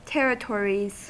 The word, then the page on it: territories: American English